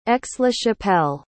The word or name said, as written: aix-la-chapelle